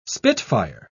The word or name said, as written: spitfire